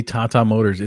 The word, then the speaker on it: Tata Motors: That name sounds, how would you pronounce that name? Either of you? Is it actually Tata Motors? – I think it might be Tata Motors. – Okay. – It is actually Tata Motors.